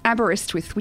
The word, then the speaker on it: Aberystwyth: It's Aberystwyth, which is a small town in the middle of Wales.